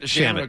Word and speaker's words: Shamet: Is it Shamet or Shamet? – Shamet. – Okay, I didn't know if it was the French pronunciation. – No, it's Landry Shamet.